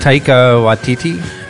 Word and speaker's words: Taika Waititi: Taika Waititi? – Yes.